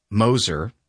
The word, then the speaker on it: Moser: Then the county prosecutor, Mike Moser, silent G, G-M-O-S-E-R, appealed the county's own board to the Common Pleas Court.